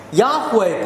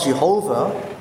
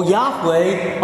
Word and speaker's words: Yahweh: It's spelled in capital, L-O-R-D. It's translating the covenant name of the Lord. We don't know how to pronounce that name, but we know the four consonants, the four-letter word. Holy word, the name of God, Y-H-W-H. Yahweh, perhaps. We're almost certain that it's not Jehovah in terms of pronunciation.